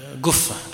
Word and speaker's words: قفة: فإذا وجدت في كتب الشناقيط الكاف المعقودة فتعلم أن هذا الحرف الذي ننطقه، فهو هذا جده أحمد كنيته أبو قفة، وينطقونها نطقا دارجا قفة أبو قفة. فالناظم هو محمد بن أحمد، هو أحمد بن محمد ابن أبي قفة، واشتهر عند بعض الطلبة ابن أبي كف أو كف أو كف، لأنه لا يعرف كيف ينطقها الشناقيط، وإلا فهم ينطقونها هكذا نظم ابن أبي قفة.